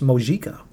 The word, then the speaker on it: Mojica: and I think his name is actually pronounced Mojica. – Mojica Marans? – Yeah, I think some of them are kind of rolling it, but some of them are hitting the J kind of hard, and he's in the room, so clearly, you know, he's not objecting to their pronunciation of his name. So, hey, I it's not like a Spanish thing where the J suddenly becomes a completely different letter that it shouldn't be.